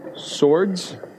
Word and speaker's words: Swords: Swords, like the fighting kind of swords.